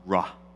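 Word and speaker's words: ra (רע): The Hebrew word for evil is pronounced ra, and the Hebrew word for good is pronounced tov.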